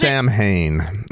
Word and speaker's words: Samhain: I believe it's pronounced Samhain. Just wanted to jump in. It's pronounced Samhain, I'm pretty sure.